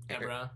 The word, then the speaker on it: Ebron: So tight ends to look at for me, Ertz, Olsen, Hooper, Doyle, and Ebron. Is that how you say his name? – Ebron. – Eric Ebron? – I believe so.